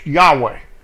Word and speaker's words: Yahweh: And in the Hebrew, it is Yod, He, Waw, He, Y-H-W-H. the Tetragrammaton, the holy name, pronounced Yahweh, if you want to put vowels to it.